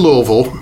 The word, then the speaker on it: Louisville: So you went to Louisville. You're a big – did I say that right? Did I say Louisville, right? – Close. Okay. You didn't quite swallow it enough.